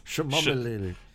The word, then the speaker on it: chamomile: But no, I need some chamomile tea. Is that how it's pronounced? – Chamomile. – Chamomile. Chamomile. – That's the one.